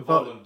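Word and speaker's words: Wołyń: And the Volyin, I think it was. I forget the exact pronunciation, but that was like 100K. – The Volyin, W-O-L-Y-N.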